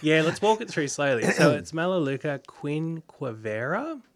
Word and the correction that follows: quinquenervia: Quinquenervia. – Oh, quinquenervia. I always drop a few syllables.